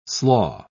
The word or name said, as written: slaw